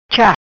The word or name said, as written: Cha